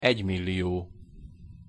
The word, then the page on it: egymillio: Hungarian